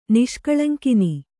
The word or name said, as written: ನಿಷ್ಕಳಂಕಿನಿ